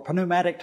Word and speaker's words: pneumatic: We get pneuma for pneumatic tires, without the P being pronounced, meaning that there's air in them, okay, pneumatic tires.